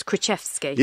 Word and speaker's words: Krichefski: Right, I've listened to the pronunciation of your surname. I know I've done it quite a few times on stage, but it's Krichefski.